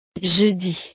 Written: jeudi

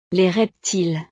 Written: les reptiles

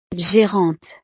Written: gérante

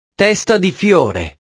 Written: testa di fiore

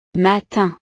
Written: matin

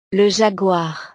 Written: le jaguar

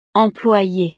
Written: employee

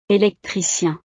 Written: electricien

